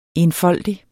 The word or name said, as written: enfoldig